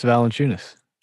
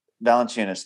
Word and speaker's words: Valanciunas: Jonas Valanciunas. – Valanciunas.